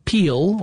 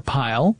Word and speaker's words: pile: and a voltaic peel or pile, depending upon how you want to pronounce it, attached at one end of the metal tube, and the other end was to an electrode.